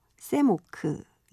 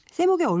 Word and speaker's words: 샘 옥: 저는 이분의 음악을 듣고 당연히 외국 사람이라고 생각을 해서 샘 오크 이런 식으로 발음했던 기억이 납니다. 그런데 아마 옥태견과 같은 그런 옥시인가 봐요. 옥샘 이렇게 되겠죠? 샘옥의 얼굴을 보면 교단에서 계신 국어 선생님 같은 인상인데요.